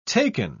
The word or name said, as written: taken